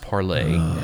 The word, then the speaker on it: Parler: It's pronounced parlay, and I know it's spelled parlor, but it's pronounced.